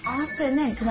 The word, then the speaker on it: often: 근데 often은 그냥 어에 fin을 붙이는 거라고 생각하면 돼요. 네. often. – often. – 네. 이 발음 안에 단어를 보면 O-F-T-E-N 이렇게 T 발음이 이렇게 T가 보일 거예요.